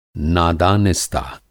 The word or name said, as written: नादानिस्ता